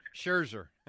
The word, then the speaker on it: Scherzer: How do you say that? – Yeah, Scherzer. – I'm with you on that. Scherzer.